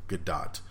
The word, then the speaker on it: Gadot: It is officially Gadot. It is basically pronounced G-A-D-O-T-T, Gadot.